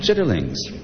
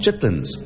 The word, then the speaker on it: chitterlings: And I pronounced it chitterlings. Well, it's pronounced chitlins, as it is in every single dictionary.